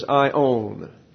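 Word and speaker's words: aion: The word forever is ion. It's not the pronoun I and the word O-W-N. It's an alpha I-O-N. And the A-I is a diphthong pronounced like a long I. I-on. Long O. I-on makes reference to the resurrection body.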